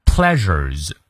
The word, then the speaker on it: pleasures: Pleasures，哎，中间发 r r r 的音，呃，介于之和日之间。